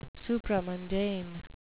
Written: supramundane

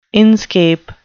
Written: inscape

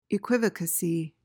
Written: equivocacy

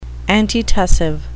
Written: antitussive

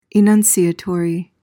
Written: enunciatory

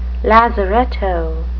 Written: lazaretto

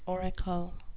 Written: auricle

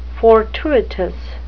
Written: fortuitous

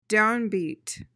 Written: downbeat

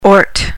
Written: ort

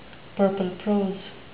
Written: purple prose

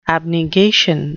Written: abnegation

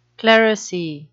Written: clerisy

